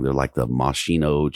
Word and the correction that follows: maraschino: cherries or whatever, those real red ones are like cocktail cherries or some shit. – Yeah, the maraschino cherries. – There you go.